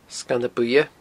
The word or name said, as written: Sganna Buidhe